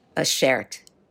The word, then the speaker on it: beshert: Tell me about the word beshert. Am I pronouncing that right? – Beshert, yes.